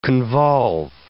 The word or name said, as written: convolve